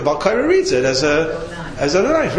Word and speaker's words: Adonai: You pronounce it Adonai any way you look at it. If it's Adoni times three, it's Adonai.